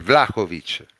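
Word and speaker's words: Vlahović: Perché non lo chiamiamo, non lo pronunciamo come si deve? È Vlahovic. – Vlahovic, sì, il problema è Vlahovic. Vlahovic. – Il problema è che l'hanno sempre pronunciato, diciamo, male, e quindi...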